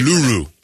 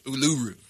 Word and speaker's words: Uluru: Are we pronouncing it right, or is that the white? – Uluru. – Uluru.